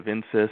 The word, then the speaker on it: Insys: I think that's how you pronounce it. They have Insys. – Yep.